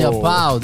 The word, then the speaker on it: Japão: De Japão, de ¿Cómo se dice Japón en brasileño? En, portugués, Japão, ¿no? ¿Cómo, cómo? Japão. ¿Japão? Creo. Japão. Japão. Japão. No, no, no. No, Japão. Japão.